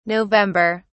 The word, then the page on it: november: British English